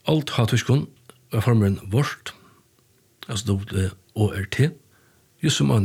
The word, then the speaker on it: Waurd: Och stort lite är att agotiskon var det Waurd, W-A-U-R-D, men A-U uttales som Å, så her var det Waurd.